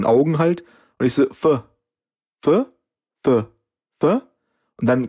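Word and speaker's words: Phở: Die Deutschen würden ja aussprechen, Foga. – Mhm. – Und Vietnamesen sagen, glaube ich, eher Pho.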